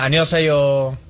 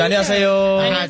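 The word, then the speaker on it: annyeonghaseyo (안녕하세요): Hola, annyeonghaseyo. Pero sí, cantadito, annyeonghaseyo. – Como cantadito, ¿no? – Ajá, cantadito.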